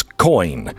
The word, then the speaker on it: Coign: It is spelled COIGN, like sovereign is spelled, but it's coin. Coin. COIGN. It's the only conservative credit card.